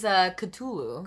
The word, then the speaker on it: Cthulhu: It is Cthulhu.